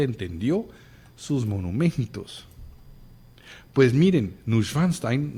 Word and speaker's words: Neuschwanstein: El castillo de Neuschwanstein, perdón, mal pronunciado, Neuschwanstein, y otros castillos en Europa no son otra cosa que un monumento al alma.